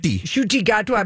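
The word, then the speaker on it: Ncuti Gatwa: Because how do you say his name again? – Shuti. Shuti Gatwa.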